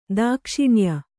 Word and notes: ದಾಕ್ಷಿಣ್ಯ: dākṣiṇya

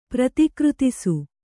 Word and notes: ಪ್ರತಿಕೃತಿಸು: Kannada

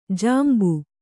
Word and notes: ಜಂಬು: Kannada

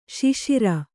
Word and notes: ಶುಷಿರ: śuṣira

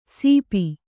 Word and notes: ಸೀಪಿ: Kannada

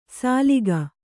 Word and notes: ಸಾಲಿಗ: sāliga

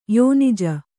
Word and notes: ಯೋನಿಜ: kannada

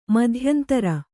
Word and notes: ಮಧ್ಯಂತರ: madhyantara

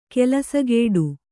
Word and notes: ಕೆಲಸಗೇಡು: kelasagēḍu

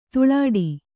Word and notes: ಧೂಳಾಟ: dhūḷāṭa

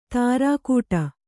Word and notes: ತುರುಕುಡಿ: turukuḍi

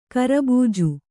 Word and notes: ಕೇರಬೀಜ: kēra bīja

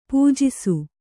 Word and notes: ಪೂಜಿಸು: pūjisu

